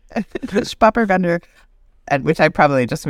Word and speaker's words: Spätburgunder: Spätburgunder.